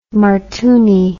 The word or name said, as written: martooni